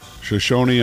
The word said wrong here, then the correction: Shohei: Ohtani? – Yeah. Shohei Ohtani. – There we go.